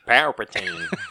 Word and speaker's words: Palpatine: Palpatine? – Palpatine. – How am I in college? I really have to ask these questions. All right. Following a broadcast by the late Emperor Palpatine, Kylo Ren obtains a Sith Wayfinder device on the planet Mustafar, leading him to the uncharted planet Exegol.